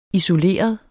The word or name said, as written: isoleret